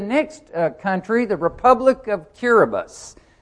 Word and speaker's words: Kiribati: Now, in that country, when the letters T-I are on the end of the word, it's pronounced S. So listen to how we pronounce that, Kiribati. And when I always pronounce that, they'll go, Kiribati. And I'll say, no, Kiribati, because the T-I is pronounced S.